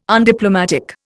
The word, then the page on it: undiplomatic: Indian